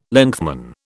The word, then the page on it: lengthman: British English